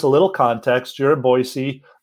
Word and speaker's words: Boise: So yeah, I live here in Boise. Good correction on the way to pronounce that. Well done on that.